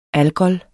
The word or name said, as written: ALGOL